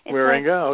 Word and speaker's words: Wierenga: First of all, am I pronouncing that right? Wierenga? – Pretty close, yeah, Wierenga. – Wierenga, like, okay. – Yeah. It's a Dutch name.